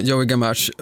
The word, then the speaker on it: Joey Gamache: Om vi blickar bakåt en sekund så hade du en tränare då som hette Joey Gamache, är det rätt uttal? – Joey Gamache.